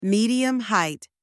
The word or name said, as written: medium height